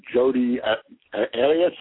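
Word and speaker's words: Jodi Arias: They're obsessed with Jody Arias.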